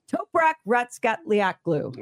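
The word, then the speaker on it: Toprak Razgatlıoğlu: How do you say his name once again? – Toprak Ratskatliaklu. – All right.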